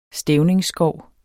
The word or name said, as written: stævningsskov